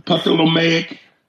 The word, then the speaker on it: Ptolemaic: Ptolemaic. – Yeah, you got it the way you spelled it. – That's funny. Ptolemaic? – Yeah. – That's funny. You got it the way you spelled it. Her Ptolemaic family came into power when Alexander the Great of Macedonia, Greece conquered Egypt.